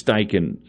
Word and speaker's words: Steichen: Is it Steichen? Am I saying that right? – Yeah. – I think it's Steichen is the pronunciation on that.